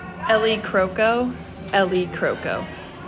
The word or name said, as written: Ellie Croco